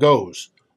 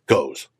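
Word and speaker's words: Gose: I think that's how you pronounce it. It's a weird O and then a O with some weird symbol above it. Z-E is how it's pronounced. So I said Goes.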